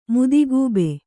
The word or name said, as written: ಮದಗೊಬ್ಬು